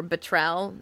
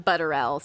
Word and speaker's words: Butterell: The choreographer was Jonathan Butterell. Or Butrell. It's spelled Butterell, so I'm going to say it like that.